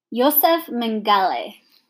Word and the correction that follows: Mengele: I don't think you said that right. – Mengele. It's German, so it's Mengele.